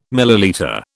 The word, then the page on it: millilitre: British English